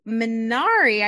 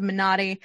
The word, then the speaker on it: Minari: Let's move on to Minari, I believe is how everyone's mostly been saying it, although I have heard it pronounced Minotti.